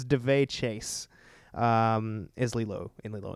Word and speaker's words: Daveigh: I believe it's pronounced DeVay. It's DaveIgh.